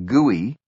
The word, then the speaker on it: GUI: This graphical user interface, or GUI, pronounced gooey, was facilitated by another concept pioneered at Xerox PARC, bitmapping.